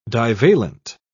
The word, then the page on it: divalent: British English